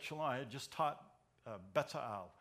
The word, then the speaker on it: Bezalel: And he says, no, that's right, Bezalel. Bezalel, the way he said it was really nice.